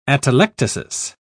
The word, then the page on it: atelectasis: British English